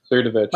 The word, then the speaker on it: Cernovich: I can't pronounce his last name, Mike Sirabuck, I think it's Cernovich. – Cernovich.